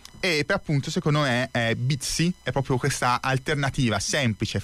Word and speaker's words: Bitsy: Si scrive Bitsy come bit più S-Y.